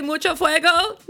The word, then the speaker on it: mucho fuego: Mucho fuego? – Yes, you are.